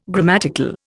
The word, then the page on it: grammatical: Indian